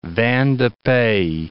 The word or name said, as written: vin de pays